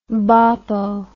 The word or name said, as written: boppo